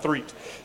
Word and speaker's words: Threet: If you don't know me, my name's Josh Threet. My name's spelled really weird, but you say it like three with a T on the end. Just keep that in mind, Threet.